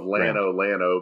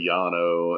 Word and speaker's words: Llano: Just like, you know, we call the town of Lano, but it's really Llano, and, you know, we fuck all the names up in Texas. We took all of the Spanish names and then pronounced them like dumb white people.